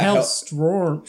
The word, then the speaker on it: Hellstrom: And possibly Hellstorm. – Mm-hmm. – Yeah. Hellstrom. Which I just noticed today. I've been saying it wrong my entire life.